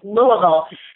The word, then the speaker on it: Louisville: Right, it's Louisville, correct? – It perfectly, yeah, perfect pronunciation. It's Louisville,